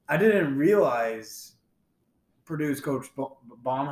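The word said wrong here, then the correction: Brohm: how do you say it, Baum? – Brom.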